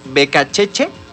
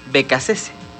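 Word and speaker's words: Beccacece: Puede pronunciarse como Becacheche o Becacese. Está bien de las dos formas. Uno es la pronunciación, digamos, italiana, que es ese apellido italiano, y la otra es, digamos, españolizada.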